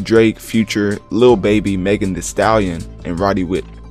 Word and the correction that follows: Roddy Ricch: um, anyways, Roddy Ricch. Y'all didn't hear that last uh, pronunciation.